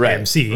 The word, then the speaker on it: MC: Or as he pronounces the name, Mick Hammer. – I like it because he doesn't realize that it's pronounced MC.